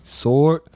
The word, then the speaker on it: sword: They don't pronounce the W in sword. – It's sword. – Who don't? – That's how it's. – We don't pronounce it, but it is. – It's a W. It's silent.